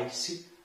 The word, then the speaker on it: ice: Além disso, pessoal, ice, que significa gelo, a pronúncia do i é i, por isso que a gente fala ice, ok?